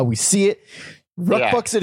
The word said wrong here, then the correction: Rucksack: I mean, sorry, Rucksack.